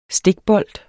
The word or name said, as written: stikbold